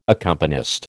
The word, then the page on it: accompanist: American English